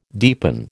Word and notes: deepen: American English

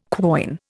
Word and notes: quoin: Indian

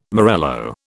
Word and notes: morello: British English

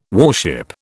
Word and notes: warship: British English

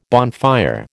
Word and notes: bonfire: American English

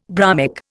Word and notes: bromic: Indian

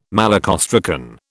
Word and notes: malacostracan: British English

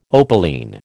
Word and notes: opaline: American English

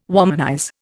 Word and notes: womanize: Indian

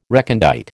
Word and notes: recondite: American English

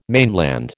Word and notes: mainland: American English

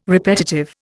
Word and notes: repetitive: Indian

